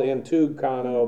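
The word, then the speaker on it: entynchanō: Again, while the word is actually spelled entuncano, but by Greek rules of pronunciation, when you have two hard consonants together, the first one is pronounced like an N. So I'm aware that it's spelled one way and pronounced another.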